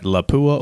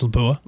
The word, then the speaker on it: Lapua: Is it Lapua or? – I think it's Lapua.